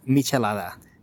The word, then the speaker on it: Michelada: The correct pronunciation for the drink is Michelada.